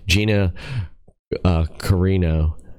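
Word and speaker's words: Gina Carano: So Hobbs with Gina Garona. I can't even pronounce her damn name, man. What the hell is her name? Gina Carino.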